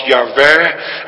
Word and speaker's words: Yahweh: Want let daarop, het gaat hier niet over de naam Adonai, maar het gaat hier over de naam die waarschijnlijk werd uitgesproken als Yahweh, en sommigen maken daar Jehovah van.